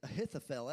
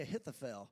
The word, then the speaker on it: Ahithophel: but his name is Ahithophel. Everyone say Ahithophel.